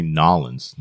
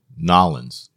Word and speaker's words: New Orleans: Or as they say, Nollins. – Yeah, we don't say it correctly.